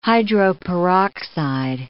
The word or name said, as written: hydroperoxide